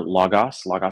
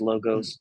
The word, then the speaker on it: Logos: I work for Logos Bible Software, also pronounced Logos. Both pronunciations are fine.